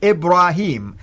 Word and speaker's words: Ibrahim: And it is Abraham, like this. And the second time, it's actually with the yeah, the way the Quran pronounced it, Ibrahim.